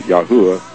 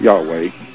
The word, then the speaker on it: Yahweh: which really should be pronounced Yahuwah, not Yahweh.